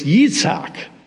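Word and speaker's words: Yitzhak (יצחק): We pronounce it differently in Arabic than in Hebrew, but in Hebrew it's Yitzhak. In Arabic it's Yitzhak.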